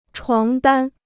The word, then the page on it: 床单: chuáng dān